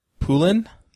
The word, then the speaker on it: Poulin: Is it Poulin? – Poulin or Poulin.